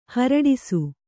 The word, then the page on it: ಹುರುಡಿಸು: Kannada